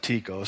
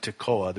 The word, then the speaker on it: Tekoa: The reason I pronounce it Tico is because that's the town I grew up in in Washington State, small farm town, and that's what they called it, Tico, Washington. So, Tico, my hometown, it was a wilderness.